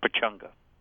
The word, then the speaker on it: Pechanga: Yeah, as though there were you in the middle of there instead of a Chang, so it's Pechanga. – Well, a lot of your patrons are of Mexican heritage, and they would pronounce that A as Pechanga. – Right. – And so whether it's Indian or Mexican, it would come out the same.